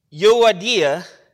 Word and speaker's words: Euodia: I implore Euodia and Syntyche. That's right. I did not mispronounce it. I practiced those names. That's how you do it.